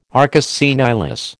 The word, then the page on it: arcus senilis: American English